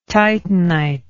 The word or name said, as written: titanite